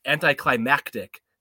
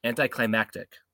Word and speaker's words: anticlimactic: Nope. You have to say you have to hit the X on climax. Anticlimactic. You have to hit like a CT. Anticlimactic.